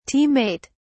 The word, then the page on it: team mate: British English